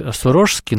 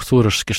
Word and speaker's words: сурожский: Я слышал такое обоснованное мнение, что с точки зрения грамматики и исторических реалий нужно говорить сурожский, но настолько уже в плоть языка вошло употребление с ударением на первый слог сурожский, что я уже не смогу переучиться и буду это продолжать делать. К тому же, что духовные чады владыки, которые его знали, которые у него в храме окормлялись, тоже так произносили.